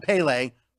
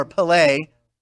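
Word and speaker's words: Pelé: And by, speaking of soccer, a posthumous birthday to the great icon, soccer icon Pele, or Pele, different pronunciations right there, Pele, yeah, world celebrated.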